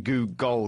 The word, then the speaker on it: googol: So they called it a Googol, and you could hear him emphasising Googol because it's spelt G-O-O-G-O-L. And in fact, our Google search engine is named after this, but they spelt it differently. So they spelt it with an L-E instead of an O-L.